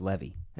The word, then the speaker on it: Levy: I think it's Levy. – Is it Levy? – I believe it's Levy.